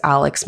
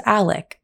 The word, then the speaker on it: Alex: But the way his name is spelt, you would think his name is Alex Murdaugh. However, his name is Alec Murdaugh.